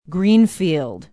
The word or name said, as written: greenfield